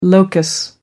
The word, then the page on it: locus: American English